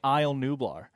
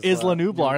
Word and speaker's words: Isla Nublar: The entire episode, I was saying Isle Nublar. – Oh, instead of Isla Nublar.